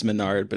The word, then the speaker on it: Mesnard: My last name is Menard, but it's spelled Mesnard.